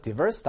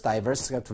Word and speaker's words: diverse: 자, 그래서 읽는 사람에 따라서 diverse, diverse가 둘 다 가능하니까 자, 요 발음 일단 주의하시기 바라고요.